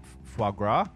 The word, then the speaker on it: foie gras: I will say that the first time I went back to eating meat, I tried, I'm gonna say this wrong, foie gras. – There you go. – Right? – Yeah, you got it.